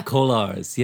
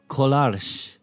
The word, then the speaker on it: Kolars: Is that how you pronounce it? – Yeah, glam rock or duo or Kolars? Glam rock. Kolars, yes. In Czech, it would be Kolars.